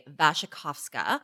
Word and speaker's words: Wasikowska: Okay, so I'm saying it in a fancy Polish way, Wasikowska. – Uh-huh. – That is the way it's pronounced, and I happen to be married to a Pole, and if I did not pronounce it properly, I would get dragged.